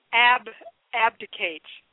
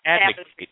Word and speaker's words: abdicate: What is abdicate? Abdicate. A-B-D-I-C-A-T-E. Just ab. – Not advocate. Like, I'm going to advocate, I'm going to, you know, for chicken.